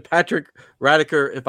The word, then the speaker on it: Patrick Radecker: This is my friend Patrick Radecker, hopefully I pronounced his last name.